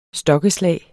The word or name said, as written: stokkeslag